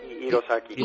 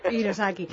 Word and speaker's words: Hirosaki: ¿cómo se pronuncia? Hirosaki, con la H española, normal. – Hirosaki.